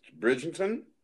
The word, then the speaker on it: Bridgerton: Oh yeah, you gotta take, check out Bridging Turn, Bridging Turn, Bridger Turn. I can't even pronounce it. It's good as hell. Mm. But yeah, that's whatever the hell that is, Bridgerton. Yeah, Bridgerton. I don't, I never seen that word.